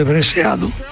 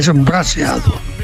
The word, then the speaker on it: braseado: Pero es una técnica interesante y ya digo que los cocineros tradujeron por algo breseado, porque brasa en francés es bres y por la E de la pronunciación sacaron lo de breseado, cuando en realidad es braseado.